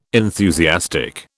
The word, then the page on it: enthusiastic: British English